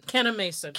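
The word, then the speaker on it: Kanneh-Mason: Kenai Mason. – Kenai Mason.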